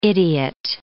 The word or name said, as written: idiot